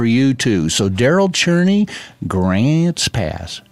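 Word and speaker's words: Grants Pass: We played Grant's Pass. That's how you say it.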